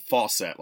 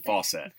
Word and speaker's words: Fawcett: Yeah, so we just got in a whole bunch of Captain Marvel, like, Fawcett, like Shazam comics from, like, the 40s.